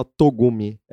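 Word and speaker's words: Tsugumi: E as indicações são: uh, se escreve Tissogumi, é, é um romance, mas é fala Togumi.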